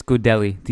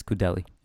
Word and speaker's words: Gudelj: And then I'm going to butcher this guy's name, this Serbian player they picked up from Sporting, Gudelj, I guess that's how you say it. – It's Gudelj. The J Gudelj, is, okay. According to the broadcast, it's Gudelj.